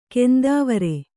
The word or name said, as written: ಕಂತೆವೊರೆ